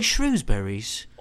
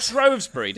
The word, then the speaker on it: Shrewsbury: The Shrewsbury tastes like Shrewsburys. – Or Shrewsbury, depending how you pronounce it. It's an actual genuine debate in the UK.